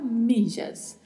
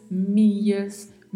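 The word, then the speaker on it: millas: Pode ser pronunciado com som de LH, de I, de J, de DJ, de X. Então depende do local. Então podemos falar milhas, mias, mijas, mijas, michas, a depender das regiões.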